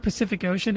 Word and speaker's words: Pacific Ocean: The word Pacific Ocean, every C in that is pronounced differently.